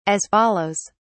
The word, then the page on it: as follows: British English